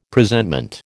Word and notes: Presentment: American English

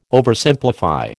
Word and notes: Oversimplify: American English

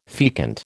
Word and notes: fecund: American English

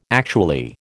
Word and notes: actually: American English

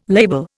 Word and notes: label: Indian

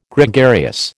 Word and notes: Gregarious: American English

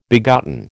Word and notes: begotten: American English

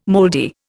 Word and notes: mouldy: Indian

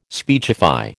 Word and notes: speechify: American English

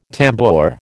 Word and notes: tambour: American English

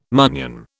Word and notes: munnion: British English

